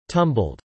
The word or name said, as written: tumbled